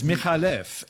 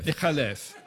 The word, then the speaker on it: McAuliffe: McAuliffe is actually an Arabic name, and it's McAuliffe, and the C is just for spelling pronunciation. – Right, McAuliffe. – McAuliffe.